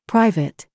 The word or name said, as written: PIPE